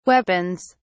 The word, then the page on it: weapons: British English